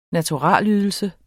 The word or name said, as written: naturalydelse